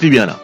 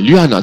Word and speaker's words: Luana: Tu vois, on prononce Luana. – Ouais, Luana.